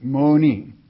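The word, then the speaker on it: moni (μονή): The word is M-O-N-E in the Greek, moni, or mone, if you wish to pronounce it that way.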